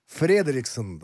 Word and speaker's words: Фредериксен: Фредериксен, на первый слог ударения,